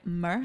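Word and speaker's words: MØ: M, like, O with the slash through it. – With the slash through it. – I think it's pronounced like Mer.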